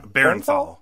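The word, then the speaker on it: Bernthal: And I can never pronounce his last name, the actor. – Berenthal. Yeah. – Berenthal. Yeah. John B.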